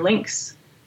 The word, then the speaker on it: Lynx: So this new tool from the Alberta Biomonitoring Institute, ABMI, is called Nature Links. And Links is spelt unexpectedly, L-Y-N-X, like the animal.